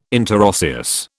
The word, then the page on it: interosseous: British English